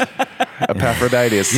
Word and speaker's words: Epaphroditus: Well, today, one of Paul's friends, Epaphroditus. Am I saying that right? – That's fine. – Epaphroditus. – I mean, it's fine. – It's our own English pronunciation.